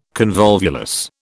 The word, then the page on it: convolvulus: British English